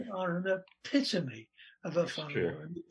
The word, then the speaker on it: whānau: And one of the words that they use is whānau, W-H, which is F in Maori pronunciation, F, W-H-A-N-A-U, whānau.